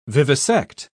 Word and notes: vivisect: British English

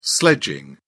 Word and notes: sledging: British English

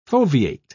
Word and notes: foveate: British English

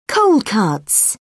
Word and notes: cold cuts: British English

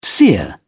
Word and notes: seer: British English

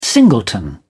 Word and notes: singleton: British English

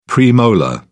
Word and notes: premolar: British English

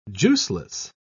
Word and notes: juiceless: British English

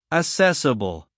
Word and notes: assessable: American English

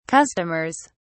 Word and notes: customers: British English

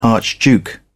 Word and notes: archduke: British English

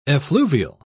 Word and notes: effluvial: British English

